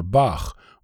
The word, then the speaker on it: Bach: and the CH sound, pronounced like the sound of the composer Bach, or the Dutch name Benschop, not to be confused with the CH in child or the CK in clock or the SH sound in shop.